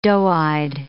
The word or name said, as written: doe-eyed